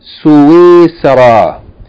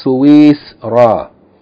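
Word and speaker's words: Suisara (سويسرا): That seen may be pronounced with sukun, as it is here written in my book, or it may be pronounced with a fatha or kasra. Suisara. It may be pronounced Suisara or Suisra. Okay, I think the more common pronunciation is Suisara.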